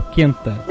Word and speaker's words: Quenta: É, é Quenta ou Quenta? – Quenta. Se pronuncia é, gente... todas as letras. Fala, fala-se todas as letras, é isso mesmo.